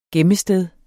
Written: gemmested

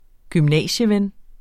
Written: gymnasieven

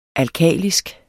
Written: ALKALISKE

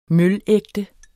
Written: mølægte

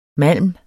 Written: malm

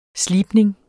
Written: slibning

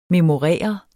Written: memorere

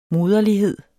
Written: moderlighed

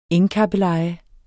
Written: engkabbeleje

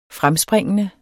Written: fremspringende

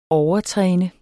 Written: overtræne

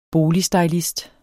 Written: boligstylist

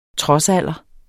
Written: trodsalder

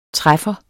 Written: træffer

